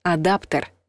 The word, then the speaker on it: адаптер: Кстати, слово «адаптер» нужно произносить твердо, через «э», «адаптер», написать все равно «е».